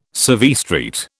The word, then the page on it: Civvy Street: British English